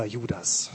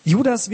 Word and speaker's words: Juda: Judas wird im Hebräischen nur Juda ausgesprochen. Eigentlich heißt der Wicht Juda. Aber die Griechen kennen nur die Version, die auf einem S endet. Deswegen wurde aus Juda ein Judas. Wahrscheinlich war er einer der leiblichen Brüder von Jesus.